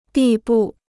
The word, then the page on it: 地步: dì bù